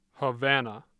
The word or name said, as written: Havana